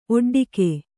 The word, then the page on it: ಒಡ್ಡಿಕೆ: oḍḍike